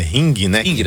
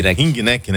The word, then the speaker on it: Ringneck: Olha só, foi encontrado também a última chamada desse papagaio, não é Rickneck, como é que fala? É Ringneck. Ringneck, né?